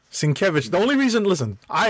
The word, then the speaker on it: Sienkiewicz: And his Twitter handle is where he puts up a phonetic pronunciation of his name. So that is why I know. Oh, yeah. That is why I am comfortable when I see his name pronouncing it. It is Sienkiewicz.